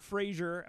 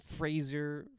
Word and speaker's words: Fraser: Fraser, not Fraser of Dr. Fraser Crane fame. – Fraser. – As in, like, he makes phrases. – Anyone have any thoughts about Brendan Fraser from your childhood and or from his recent win as an Academy Award winning actor, which is hysterical now?